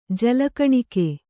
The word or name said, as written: ಜಲಕಣಿಕೆ